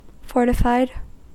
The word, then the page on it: fortified: American English